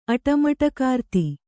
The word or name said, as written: ಅಟಮಟಕಾರ್ತಿ